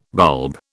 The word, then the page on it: bulb: British English